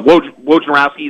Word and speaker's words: Wojnarowski: Wojnarowski, is that how you say his name? – Yeah.